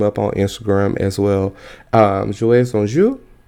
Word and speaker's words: Jouets en Jeu: Jouets en Jeu.